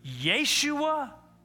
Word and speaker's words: Yeshua: He's a man whose name in Hebrew is pronounced Yeshua, translated into English, Jesus.